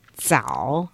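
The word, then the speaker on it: zao (枣): And a jujube is pronounced zao, so it's like early.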